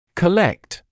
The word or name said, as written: collect